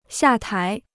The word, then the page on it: 下台: xià tái